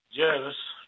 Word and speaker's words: Jervis Bay: Is it Jervis Bay or Jarvis Bay for you? – Jervis. – It's Jervis.